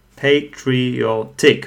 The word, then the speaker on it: patriotic: So look at patriotic. Patriotic, four syllables. And again, it's the second one from the right. Patriotic. So o is stressed.